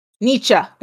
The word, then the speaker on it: Nietzsche: And Leopold reportedly urged him to do it using the concept from German philosopher Friedrich Nietzsche. I forgot how to Google how to say his name. I was going to. Nietzsche. Something like that. Friedrich. – What, we're Nietzsche or something?